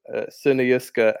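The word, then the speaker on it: Sønderjyske: Secondly, I've had some very helpful people on Twitter tell me how to pronounce these. So Sunayuska and Vela are both looking like, at the moment, relegation is going to happen, unfortunately.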